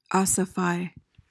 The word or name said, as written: ossify